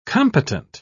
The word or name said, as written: competent